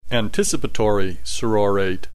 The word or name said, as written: anticipatory sorortate